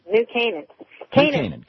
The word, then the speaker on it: New Canaan: New Canaan, Connecticut.